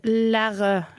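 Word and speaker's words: Lheureux: Og du må hjelpe meg med å uttale dette navnet. – Jeg ville sagt Lærø.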